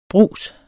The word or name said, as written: brugs